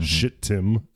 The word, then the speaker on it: Shittim: So, Israel was like chilling out at Shittim, and it's spelled like it sounds, mm-hmm. Shittim.